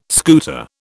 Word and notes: scooter: British English

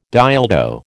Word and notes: dildo: American English

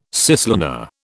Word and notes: cislunar: British English

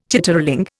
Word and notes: chitterling: Indian

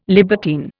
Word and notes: libertine: Indian

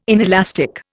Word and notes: inelastic: Indian

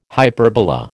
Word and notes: hyperbola: American English

